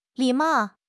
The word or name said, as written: li3 mao4